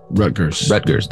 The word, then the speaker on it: Rutgers: Rutgers, how do I say that? – Rutgers, that's it.